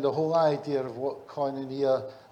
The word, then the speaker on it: koinonia: Its proper pronunciation is koinonia, and it's part of life, believe it or not.